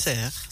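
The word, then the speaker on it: Sers: Serre, je ne sais pas comment on prononce. – Serre. – Serre, voilà. Ouais.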